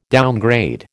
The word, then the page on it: downgrade: American English